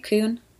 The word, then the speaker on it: Kuhn: So I chose, and I might butcher the way this is pronounced too, but I'm going to say it as Kuhn Memorial State Hospital. It's K-U-H-N, right? Kuhn? – Kuhn.